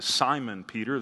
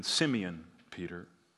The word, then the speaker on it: Simeon: And usually in the New Testament, he is referred to as Simon Peter. That pronunciation is influenced by the Greek culture. That's how they would have pronounced his name. But Peter was a Jew, and if you pronounced it in the Hebrew, it would have been Simeon Peter.